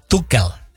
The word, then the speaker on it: Tuchel: Lo de Thomas, que ya escuché, como dice, ya dijo: mi nombre se pronuncia Thomas Tuchel. – Tuchel, ¿verdad? – Tuchel. – No Tuchel. – Yeah. Es Tuchel.